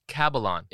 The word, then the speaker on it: Cabellon: Cabellon. – Entirely phonetic. Rhymes with Babylon.